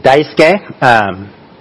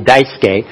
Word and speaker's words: Daisuke: If you want to say it right, if you look at my name, it's spelled with extra, I mean, the U that is completely silent. So some people call me Daisuke or something like that, but it will be silent U, so that would be Daisuke.